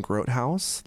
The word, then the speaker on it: Grothuis: I am on Facebook, Solomon Grothaus. Last name is spelled GROTHUIS.